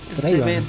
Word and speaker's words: Erzsébet: Erzsébet, bueno, así se pronuncia.